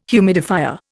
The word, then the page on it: humidifier: Indian